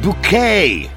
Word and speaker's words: Bucket: It's pronounced bouquet. Bucket.